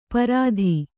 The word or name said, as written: ಪ್ರತಿ